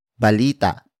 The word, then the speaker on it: balita: And that is balita.